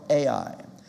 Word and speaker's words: Ai: It was a place called Ai. Ai, that's how it's spelled. We pronounce it I or Ai.